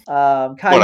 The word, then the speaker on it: Caillou: So it's pronounced like Spanish L's. I did not know that. Caillou.